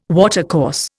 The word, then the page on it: watercourse: Indian